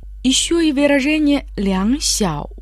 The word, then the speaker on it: 小: «Сяо» в третьем тоне имеет в виду «маленький».